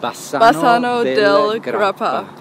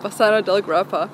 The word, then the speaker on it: Bassano del Grappa: Bassano del Grappa. – Bassano del Grappa.